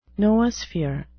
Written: noosphere

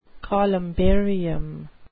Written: columbarium

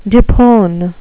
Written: depone

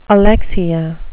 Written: alexia

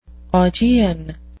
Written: augean

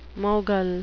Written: mogul

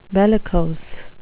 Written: bellicose

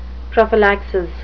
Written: prophylaxis

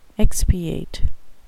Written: expiate

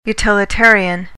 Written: utilitarian

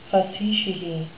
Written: facetiae

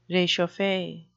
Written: rechauffe

